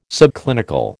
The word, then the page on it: subclinical: American English